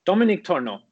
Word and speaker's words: Dominik Tornow: Dominik Tornow, yes.